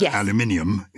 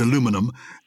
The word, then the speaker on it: aluminium: So it's spelt like magnesium and it's pronounced aluminium in the UK. – Yes. – But in America, it's aluminium and it's spelt like platinum.